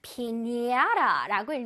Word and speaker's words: piñata: 근데 이 원래 잘 읽어보면 피나타인데요. 이 나라 멕시코의 발음으로 하면 피냐라라고 읽는다고 합니다.